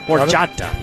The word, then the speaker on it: Horchata: National Horchata Day. Looks like a type of milk. – You said that right? – Horchata, yes.